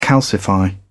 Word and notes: calcify: British English

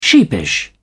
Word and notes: sheepish: British English

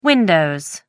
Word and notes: windows: American English

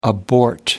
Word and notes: abort: American English

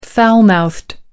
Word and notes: foul mouthed: American English

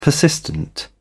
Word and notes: persistent: British English

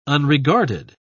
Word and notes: unregarded: British English

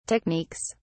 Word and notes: techniques: British English

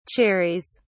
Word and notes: cherries: British English

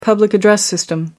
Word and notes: public address system: American English

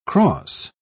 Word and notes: crosse: British English

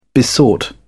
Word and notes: besought: British English

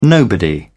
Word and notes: nobody: British English

